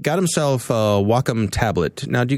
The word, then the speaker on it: Wacom: When I go to SIGGRAPH, I'd go to the booth and they would always chastise me and say it's called Wacom.